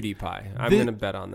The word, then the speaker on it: PewDiePie: It's spelled P-E-W-D-I-E-P-I-E. PewDiePie. – Like CutiePie. – I think so. Or PewDiePie. – No, you don't say CutiePie.